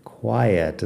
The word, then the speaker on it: quiet: Fíjate en la pronunciación de quiet. Tiene ay, quiet. Es como un triptongo ahí en medio. Tres vocales. Por último tenemos, she's from a quiet little town in the mountains.